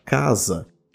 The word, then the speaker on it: casa: Quando eu falo a palavra casa, eu estou pronunciando 4 fonemas diferentes. K, A, Z, A. Repara como aquilo que a gente escreve S é pronunciado Z, por ser intervocálico.